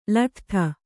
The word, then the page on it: ಲೊಡ್ಡು: kannada